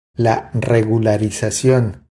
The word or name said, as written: La regularizacion